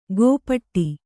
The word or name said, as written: ಗೋಪಟ್ಟಿ